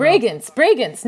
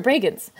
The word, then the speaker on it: Bregenz: Bregenz. How do you say it? I don't know. You know what I'm talking about? – Bregenz. No, you're right. Bregenz.